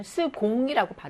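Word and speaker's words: second: 그 다음은 여러분들이, 좀 습관적으로 이거를 많이 발음을 하시는데, 영어의 세컨드 때문에 그러신 것 같은데, 이거는 스공이라고 발음합니다. 스공이라고 하셔야 돼요.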